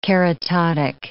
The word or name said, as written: keratotic